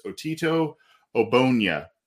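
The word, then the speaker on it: Otito Ogbonnia: The way I've understood his name to be pronounced is Otito Obonya,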